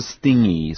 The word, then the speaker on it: stingy: 那么拼一下 S-T-I-N-G-Y, 不是 stingy, 是 stingy, S-T-I-N-G-Y, stingy.